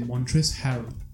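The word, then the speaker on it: Montrezl: Bueno, hay gente que le pronuncia Montres sin la L, la L es muda. Así que voy a ir de vuelta y voy a decirte Montres Harold.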